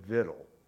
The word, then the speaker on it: victual: Now that word, I know how it looks. It comes from an old French word. We all pronounce it victual.